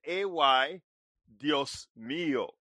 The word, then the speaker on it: Ay, Dios mío: Anyway, actually, it's not a Dios mío. It's I, meaning A-Y, Dios mío. That's how you say it, my dear.